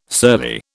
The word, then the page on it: surly: British English